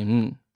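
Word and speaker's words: うん: It's written un, but you can say it with your mouth closed. You can just say un.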